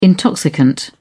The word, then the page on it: intoxicant: British English